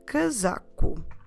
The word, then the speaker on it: casaco: For both jacket and coat, in Portuguese we can use the same word, the word casaco. Casaco. Stressing the za. Casaco. Casaco. Como se escreve casaco? C-A-S-A-C-O.